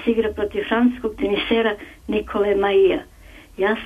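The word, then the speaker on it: Mahut: I prezime izgovara Mau. Katastrofa, ja sam rekla.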